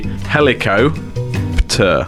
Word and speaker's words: helicopter: The two, how if you was to split it correctly, it would be helicopter. But the P is silent, so it's helicopter.